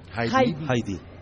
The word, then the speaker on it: HID: Correcto. Se pronuncia HID. – HID. – En inglés prácticamente son dos sílabas, ¿no?